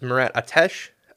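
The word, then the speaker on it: Murat Ateş: There's a lot of good reporting from, I believe it's pronounced Murat Ateche, and Arpin Basu of The Athletic, following the trail on Pierre-Luc Dubois.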